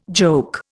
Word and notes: joke: Indian

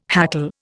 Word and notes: hackle: Indian

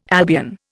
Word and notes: Albion: Indian

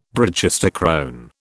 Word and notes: brachistochrone: British English

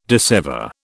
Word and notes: dissever: British English

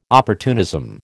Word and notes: opportunism: American English

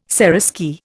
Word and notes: seraskier: Indian

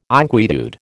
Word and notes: inquietude: American English

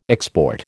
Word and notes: export: American English

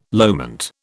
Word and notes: loment: British English

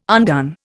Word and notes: undone: Indian

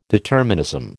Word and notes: determinism: American English

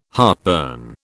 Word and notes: heartburn: British English

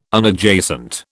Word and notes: Unadjacent: British English

